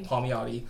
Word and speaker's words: Palmiotti: I can never pronounce his last name. – Palmiotti.